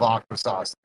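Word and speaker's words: Akwesasne: He said Aquasasni. Like we're like Aquasas. Okay, I knew I said it wrong.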